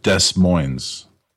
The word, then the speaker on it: Des Moines: They're near Federal Way and Des Moines, which is pronounced with the S.